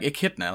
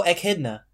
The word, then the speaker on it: Echidna: How do you spell that? Echidna? – Like Echidna, like the hedgehog. – Oh, Echidna.